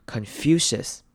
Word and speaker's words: Confucius: 来看第一个字要特别注意的是 Confucius，孔子这个字。孔子的名字要特别注意的是后面 CIUS，这边要念一个 SH 的声音，也就是 sh，所以我们会念成 Confucius，Confucius，后面可以接一个轻音 Confucius，Confucius，因为重音在第二个音节 FU 那边 Confucius。